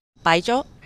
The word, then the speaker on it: Baijiu: Baijiu.